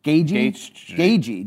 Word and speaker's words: Gaethje: He hates my Gaethje pronunciation. – Okay. – Gaethje. G-A-G-E-Y.